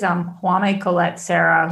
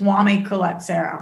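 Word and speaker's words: Jaume Collet-Serra: Yeah, I think it's Juan Colette Serra. – Thank you. Yes, because I did look it up and I found at least three different pronunciations.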